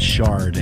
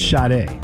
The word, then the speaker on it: Sade: It's, of course, Sade, who for many years I pronounced with an R in her name, and there's no R. – You. – Sade. It's not Sade.